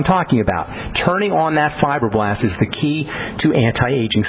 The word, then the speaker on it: fibroblast: Before you turn on the fibroblast, the cell itself, and fibroblast, by the way, is spelled F-I-B-R-O, fibro, as in fibers, blast, B-L-A-S-T, fibroblast. This is a cell that makes the collagen,